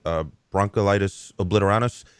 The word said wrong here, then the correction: bronchiolitis obliterans: That is bronchiolitis obliterans. – Thank you.